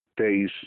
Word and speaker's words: Tisza: En los Balcanes se produjo la expansión de los sármatas iraníes, cuyos dominios se extendieron desde el sur de Rusia hasta la Tisza, Teis se pronunciaba, y el Danubio, donde se convirtieron en peligrosos vecinos del imperio.